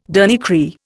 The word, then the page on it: dernier cri: Indian